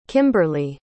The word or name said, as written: kimberley